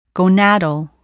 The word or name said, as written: gonadal